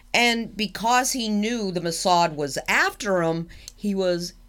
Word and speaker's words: Mossad: Mossad. – Mossad agency killed a Palestinian wanted for airplane hijacking by feeding him poisoned Belgium chocolates for over 6 months.